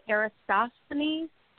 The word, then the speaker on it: Eratosthenes: Like, I question, like, the flat earth thing, but I think his name, how do you say it, Eratosthenes? Did I say that right? – Yes.